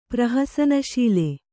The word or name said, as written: ಪ್ರಹಸನಶೀಲ